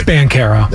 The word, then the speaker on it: Banchero: I don't care that Banchero, whichever way pronunciation you want to, I don't care that he's good. – It's Banchero.